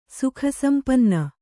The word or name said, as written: ಸುಖಸಂಪನ್ನೆ